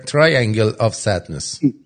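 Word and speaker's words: Triangle of Sadness: بعد یه فیلم هم هست به اسم ترانگل آف سادنس، اگه اشتباه تلفظ نکرده باشم، مثلث غم، تراینگل آف سادنس.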